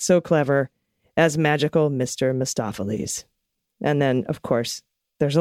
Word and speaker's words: Mistopheles: The magical Mr. Mistopheles rhymes with McLotsafleas.